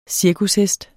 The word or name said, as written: cirkushest